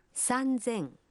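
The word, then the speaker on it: санзен (三千): Но и здесь произношение меняется. 3000 будет звучать как санзен.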